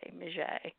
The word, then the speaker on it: Miget: thing we could do to try to, you know, explain to people how to pronounce it, M-E-E-J, Miget, yeah.